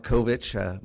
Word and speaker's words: Kovich: My name is Mark Kovic. My name is spelled M-A-R-K, last name K-O-V-I-C-H.